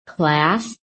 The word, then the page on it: CLASS: American English